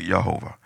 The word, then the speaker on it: Yehovah: We say Jehovah, it's actually Yehovah.